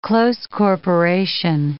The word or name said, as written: close corporation